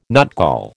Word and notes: nutgall: American English

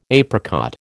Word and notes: apricot: American English

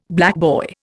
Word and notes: blackboy: Indian

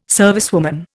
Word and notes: servicewoman: Indian

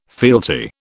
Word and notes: fealty: British English

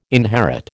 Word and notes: inherit: American English

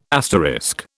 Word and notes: asterisk: British English

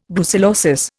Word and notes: brucellosis: Indian

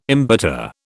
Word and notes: embitter: British English

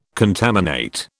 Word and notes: contaminate: British English

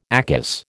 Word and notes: ACAS: American English

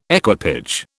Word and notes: equipage: British English

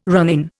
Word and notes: ronin: Indian

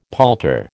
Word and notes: palter: American English

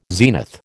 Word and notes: zenith: American English

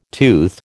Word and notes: tooth: American English

